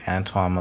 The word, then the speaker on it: Antwon: Antoine Malone Chapman. A-N-T-W-O-N. And the last name is C-H-A-T-M-O-N.